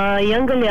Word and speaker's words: Jungle: Jungle je v bistvu nova beseda, ki je izložena iz angleške besede young, mladi, in pa jungle, džungla, tako da se izgovori jungle.